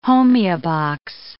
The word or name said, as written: homeobox